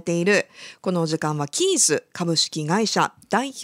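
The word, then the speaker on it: KEYES: さあ、野依さん、この会社名なんですけれども、はい、アルファベットで KEY ES と書いてキーズとなっています、はい、 KEY が大文字、そして小文字で ES、はい、と、ね、書かれているんですけれども、はい、どんな会社なんでしょうか。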